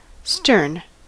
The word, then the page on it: stern: American English